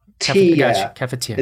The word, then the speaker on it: cafetière: T-E-A, like the word tea, the tea you drink, dash I-E-R-E, like kaffir, tea. cafetière. It's a bit, almost the same.